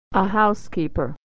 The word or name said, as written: housekeeper